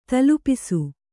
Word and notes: ತಲೆಪೂಸು: tale pūsu

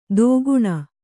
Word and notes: ದೋಗುಣ: dōguṇa

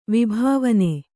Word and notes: ವಿಭಾವನೆ: vibhāvane

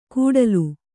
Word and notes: ಕುಡಲು: Kannada

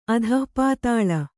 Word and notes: ಅಧಃಪಾತಾಳ: adhahpātāḷa

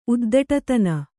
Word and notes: ಉದ್ಧಟತನ: uddhaṭataa

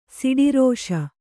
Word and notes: ಷಡ್ರಸ: ṣaḍrasa

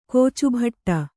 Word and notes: ಕೋಚುಭಟ್ಟ: kōcu bhaṭṭa

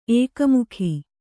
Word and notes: ಏಕಮುಖಿ: ēkamukhi

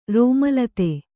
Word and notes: ರೋಮಲತೆ: kannada

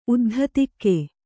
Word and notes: ಉದ್ಧತಿಕೆ: uddhatike

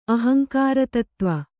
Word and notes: ಅಹಂಕಾರತತ್ತ್ವ: ahaŋkāratattva

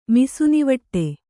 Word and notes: ಮಿಸುನಿವಟ್ಟೆ: Kannada

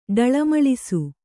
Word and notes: ಡಳಮಳಿಸು: kannada

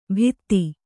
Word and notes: ಬಿತ್ತಿ: Kannada

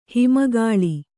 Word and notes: ಹಿಮಗಾಳಿ: hima gāḷi

